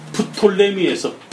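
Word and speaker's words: Ptolemy: 프톨레미에서 프라는 피라는 말이 거의 발음이 안 됐습니다. 그래서 우리가 영어식으로 해서 푸트 푸트 이리 발음하지 그냥 토 피 발음을 안 하든지 이렇게 하는 거.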